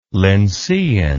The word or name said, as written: lyncean